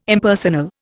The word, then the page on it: Impersonal: Indian